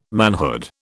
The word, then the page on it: manhood: British English